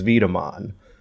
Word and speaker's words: Wiedemann: I'm pretty sure Wiedemann in proper ancestral German pronunciation is Wiedemann.